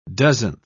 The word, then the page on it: dozenth: British English